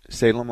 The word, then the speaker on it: Salem: Salem like the cigarette. – I don't know how to spell that. S-A-L-U-M? – E-M. – E-M. S-A-L-E-M.